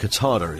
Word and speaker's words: Qatar: I think Qatar is the correct pronunciation of what I'm trying to say.